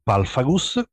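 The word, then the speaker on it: Pulpfagus: Sì, l'ho detto io perché eh, in realtà si può pronunciare anche in diversi modi. È scritto pulp, che è la prima parte, da pulp, eh, è preso dai Pulp Magazine, e fagus, che è parola latina, quindi una parola metà inglese, metà latina, quindi potete un pochino, vedete, pronunciarla come volete, ma io la, eh, dico palfagus perché mi piace di più.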